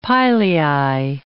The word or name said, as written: pilei